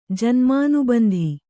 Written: ಜನ್ಮಾನುಬಂಧಿ

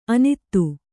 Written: ಅನತಿ

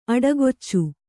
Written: ಅಡಿಕಚ್ಚು

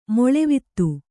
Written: ಮಲ್ಲವಾತು

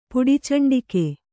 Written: ಪುಡಿಚಂಡಿಕೆ

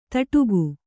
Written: ದಡಿಕೆ

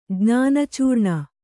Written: ಜ್ಞಾನಚೂರ್ಣ

